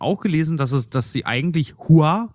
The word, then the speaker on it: Hua: Und zwar, dass dieses Fa Mulan eigentlich die kantonesische Aussprache ihres Namens wäre, was aber falsch ist, weil im Mandarin wäre es halt wirklich Hua, also H-U-A quasi in der Lautschrift,